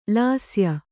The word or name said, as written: ಲಾಸ್ಯ